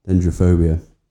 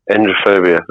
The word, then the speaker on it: Dendrophobia: Am I saying that correctly? Dendrophobia. There we go. – Dendrophobia.